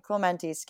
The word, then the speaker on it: Clemente: And then he was the first Diamondbacks player to win the Robert Clement and I think I pronounced his last name correctly. Roberto Clemente, excuse me.